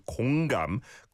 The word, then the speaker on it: 공감: We have to be a bit careful with the pronunciation because it kind of sounds like 건강, which means health, right? And so 공감, 공감 is today's hashtag. And it's an or vowel sound on 공, 공 like ball, and then 감, 감, 공감.